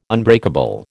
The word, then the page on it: unbreakable: American English